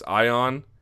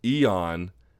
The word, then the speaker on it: Ion: Ion, or I've had it pronounced to me as Ion, Eon, and Iwan Cutelaba, defeating Henrique da Silva at 22 seconds into the first round.